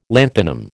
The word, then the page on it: lanthanum: American English